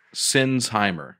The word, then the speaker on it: Sinsheimer: The H is silent. Sinsheimer.